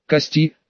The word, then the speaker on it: Кости: «Здравствуй, Кости», дружно, едва ли не хором поприветствовали они меня, сделав ударение в имени на последний слог.